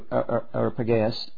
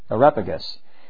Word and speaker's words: Areopagus: And they took him and brought him unto Areopagus. I didn't pronounce that right. Areopagus.